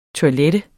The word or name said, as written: TOILETTET